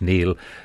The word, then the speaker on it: Niall: And he spelled his name N-I-A-L-L, pronounced Neil.